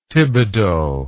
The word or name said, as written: Thibodaux